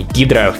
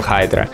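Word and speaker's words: Hydra: Тот знаменитый кадр, в котором Капитан Америка говорит «Хейл Хайдра», кстати, правильно говорить Хайдра, а не Гидра.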